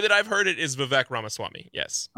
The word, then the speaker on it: Vivek Ramaswamy: And it's about this candidate named Vivek Ramaswamy, and I don't know if I've pronounced that exactly the right way, and I apologize if I've.